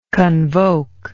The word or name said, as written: convocant